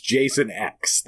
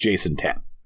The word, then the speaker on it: Jason X: But, I mean, it is the 10th movie, so – I mean, they wanted it pronounced Jason X. They didn't want it pronounced Jason 10.